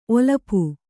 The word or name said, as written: ಒಲಪು